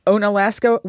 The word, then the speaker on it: Onalaska: And Frank, what about you from Onalaska, Wisconsin? Am I pronouncing it properly? – That's close enough.